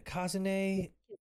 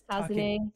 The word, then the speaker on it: Kazune: And I think Kazune also jumps, or I keep saying her name wrong, don't I? – It's like Kazune. It's Kazune. Takigawa Kazune. – Kazune.